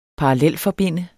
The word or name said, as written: parallelforbinde